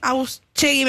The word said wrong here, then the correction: Otasegui: me parece el apellido, no lo quiero pronunciar mal en este momento, pero es uno de los directivos de la unidad penal número 31, José Otasegui. – Otasegui.